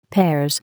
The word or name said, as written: Pears